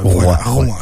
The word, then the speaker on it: Rouen: Alors, Rouen. J'ai dit Rouen. – Ça s'écrit comme ça. R-O-U-E-N.